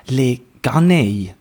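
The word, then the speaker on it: Les Canailles: Es heisst Le Ganei.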